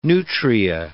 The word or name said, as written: nutria